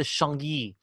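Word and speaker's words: Chagny: the Vicomte de Changi.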